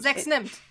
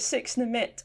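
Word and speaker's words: 6 nimmt: But I like to call it six nimit, and I don't know why. Mm. It doesn't. It's not called that no, in any way or form. – It's not spelled like that. It's six nymph.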